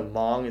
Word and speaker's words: Hmong: So Hmong is how you pronounce it.